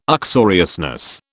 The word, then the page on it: uxoriousness: British English